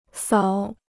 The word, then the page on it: 扫: sào